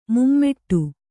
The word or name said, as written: ಮೂಮಡಿ